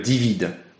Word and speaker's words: DVID: L'idée, c'est plutôt de l'appeler euh, Divid.